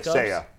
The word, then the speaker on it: Seiya: 84, Seiya Suzuki. Did I pronounce it right? – Yeah, Seiya. Say you, say me.